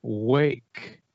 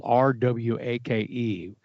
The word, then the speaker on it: Rwake: They're called Wake, but their band is spelled, the band name is spelled R-W-A-K-E. It's like, it looks like it says R-Wake.